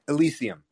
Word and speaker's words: Elysium: Well, i mean, I, I think maybe your way could be the right way, but Elysium. – it's more like E instead of ill. Uh, yeah. – Elysium, sense.